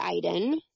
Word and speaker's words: Eiden: You can check out my Twitter, which is at Emily Eiden. So I'll spell that. It's just E-M-I-L-Y-E-I-D-E-N.